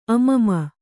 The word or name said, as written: ಅಮಮ